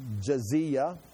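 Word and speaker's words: Jizya: He'll abolish the Jezra, which is pronounced Jezia.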